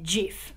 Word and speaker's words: GIF: Okay, so in the case of the GIF or the JIF, there is logic to say that it has a hard G, as you have pointed argument. Giraffe. – As in, used to pronounce GIF. Giraffe, graphic, goat.